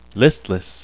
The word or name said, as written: listless